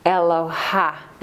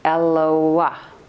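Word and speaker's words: Eloah (אלוה): So just to pronounce this word correctly, I learned this from Rabbi Kahan, we might say Eloha, right? That would be the way we... – Yeah. – Right? But it's not. It's Eloah. – Eloah. – Eloah. Because there's a dot in the hay.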